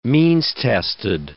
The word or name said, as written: means-tested